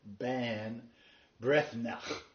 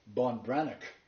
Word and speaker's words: Ban Breathnach: When I first saw her name, it literally sounds like Sarah Ban Breathnach. Okay. And I thought, how do I pronounce Breathnach? So I did a Google audio translation and found out it was Sarah, it's pronounced Sarah von Branick.